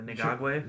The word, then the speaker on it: Ngakoue: Is that how you pronounce his last name? – Yeah, Ngakoue.